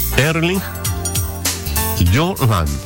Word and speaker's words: Haaland: Y su nombre se pronuncia como Erling Haaland. ¿Por qué? Bueno, le cuento de inmediato. La diferencia en noruego, el idioma, la H inicial se aspira suavemente.